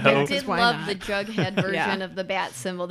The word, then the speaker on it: Jughead: Did I say his name right? – Yeah. – Jughead, yeah.